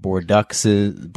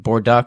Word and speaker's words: Bordeaux: Everyone was like super hype, but so many people had no idea how to pronounce Bordeaux. We had like Bordeauxes. Bordeaux was the most common mispronunciation for that.